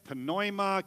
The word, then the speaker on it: pneuma: The wind in the original Greek would be, there's lots of ways to pronounce it, pneuma, because there's a P, but the P is silent, so some people say pneuma or pneuma, depending upon how you want to do it.